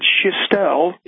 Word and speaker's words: Schestel: That's Schestel. That's exactly right. In French, I think the French side of my family pronounces it Schestel.